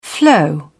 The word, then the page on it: flow: British English